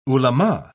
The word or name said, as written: ulema